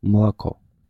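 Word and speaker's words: mlako (молоко): Chociażby takie słowo na mleko po rosyjsku pisze się tak jakby moloko, ale mówimy mlako.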